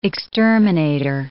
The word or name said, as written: exterminator